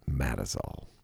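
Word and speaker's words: Mazatzal: And one of my favorite ranges in Arizona is the Matazels. Now, it's spelled Mazazal, but locals pronounce it Matazal.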